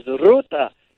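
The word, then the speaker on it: Rūta: He happens to be of Lithuanian descent and therefore says Ruta. He rolls the R.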